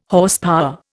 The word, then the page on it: Horsepower: Indian